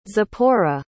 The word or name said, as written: zipporah